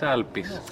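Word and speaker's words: Alpis (Άλπεις): How do you pronounce it in Greek? – In Greek it's Alpis.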